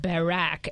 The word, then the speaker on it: Barack: No, it's hit, because in British it's Hit the Road, Barack, and so it rhymes with Jack, exactly.